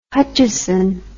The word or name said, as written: Hutcheson